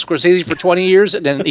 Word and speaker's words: Scorsese: It's pronounced Scorsese. – It is pronounced Scorsese, but I didn't want to get into that with you.